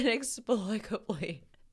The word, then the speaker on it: inexplicably: Because I think in this moment, somehow, inexplicably, is that how you say it? – Yes. – Okay, inexplicably.